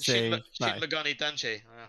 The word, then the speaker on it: Shin Megami Tensei: Shin Megami Tensei. – Shin Megami Tensei.